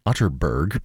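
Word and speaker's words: Atterberg: It's spelled Otterberg, but pronounced Otterberg.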